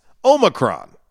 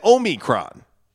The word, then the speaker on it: Omicron: That's the 15th letter of the Greek alphabet, and almost anything works because Omicron are both fine, according to the Oxford Dictionary, which actually contains Omicron as well. And in classical Greek, it was pronounced with the second syllable sounding like me, as in Omicron.